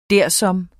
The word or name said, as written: dersom